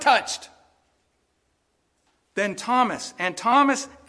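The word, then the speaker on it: Thomas (Θωμᾶς): Apokrithē Thomas is how you say his name in Greek. Thomas.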